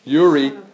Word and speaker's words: Uri: Uri.